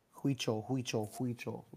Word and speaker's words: Huichol: Huichol.